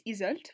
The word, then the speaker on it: Yseult: I looked it up and it's Isolt, which is actually such a pretty name. It's spelled Y-S-E-U-L-T, so that's really cool.